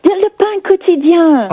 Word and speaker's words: Le pain quotidien: Ah oui, le pain quotidien. – Oh, is that how you say it? – Yes.